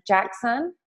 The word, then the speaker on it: Jackson: So it's T-O-N-I-A and then Jackson, J-A-C-K-S-O-N. Last name is O'Neill, which is O-N-E-I-L-L.